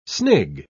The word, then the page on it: snig: British English